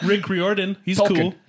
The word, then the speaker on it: Riordan: It's pronounced Riordan. Riordan.